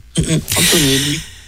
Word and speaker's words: Antonelli: Antonelli. Un peu à l'italienne, en fait, finalement, hein, comme on prononcera en italien. Antonelli.